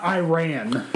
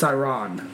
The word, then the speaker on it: Iran: And he said, by the way, it's not Iran. It's Iran. So let's get that straight.